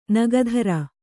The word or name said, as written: ನಗಧರ